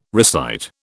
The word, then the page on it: recite: British English